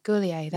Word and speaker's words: Goulier: Goulier is French, isn't he? – Goulier, that's how you say it.